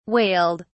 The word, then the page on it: wailed: British English